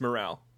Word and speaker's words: Morale: Oh, you mean Miral. I say Miral. – It's Morale. – Oh, it is? – Yeah. – Really? Am I the only one that's had this wrong? – Yeah. You don't worry. You pronounce things odd all the time anyway, so you know, it's cool. – So I'm told, but wow. – Yeah, morale. – All right, then. – Or like it's morale. Like it's morale, not mural, because she's named after her mother.